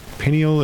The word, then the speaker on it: pineal: I wanted to thank you for that, clearing out that, how do you pronounce it, pineal or penial? – Pineal.